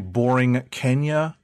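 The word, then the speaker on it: Borinqueña: I believe that's how to pronounce it right. Borinquenia. – No, the creator of the book actually told me in person that it's, if you can say Borinquenia, that's essentially what it's pronounced like.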